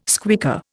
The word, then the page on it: squeaker: Indian